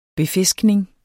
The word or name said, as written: befiskning